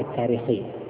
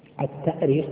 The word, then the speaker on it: التأريخ: طبعا من حيث الأصل الاشتقاقي التأريخ بالهمز، لأنها أرخ يؤرخ، الهمز أصلي فيه، أرخ يؤرخ تأريخا. وأما التاريخ فهو تسهيل،